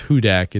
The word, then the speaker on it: Hudak: And James, I like to tell her with a last name that's pronounced Hudak, it's only fitting that she covers the Saints, right? – Right, exactly.